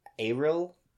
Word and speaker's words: aril: But this only happens if you eat the ackee fruit arils. Aril? A-R-I-L. Am I saying that right? Aril? – Yeah.